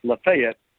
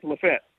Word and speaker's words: Lafayette: And by the way, since I've been here, you and I thought it was pronounced Lafayette. It is not. It's Lafayette. – Stop it. – We've been saying it wrong. – Come on, Lafette. – That's what the locals tell me, Lafette.